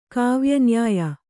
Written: ಕಾವ್ಯನ್ಯಾಯ

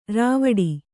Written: ರೂವಿಡು

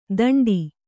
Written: ಥಂಡಿ

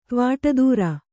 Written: ತ್ವಾಟದೂರ

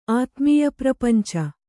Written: ಆತ್ಮೀಯಪ್ರಪಂಚ

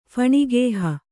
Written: ಫಣಿಗೇಹ

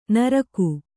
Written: ನುರ್ಗು

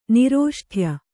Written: ನಿರೋಷ್ಠ್ಯ

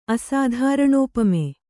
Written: ಅಸಾಧಾರಣೋಪಮೆ

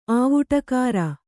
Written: ಆವುಟಕಾರ

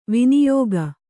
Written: ವೈನಾಯಕ

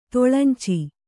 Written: ತೊಳಂಚಿ

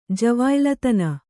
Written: ಜವಾಯ್ಲತನ

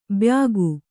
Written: ಬ್ಯಾಗು

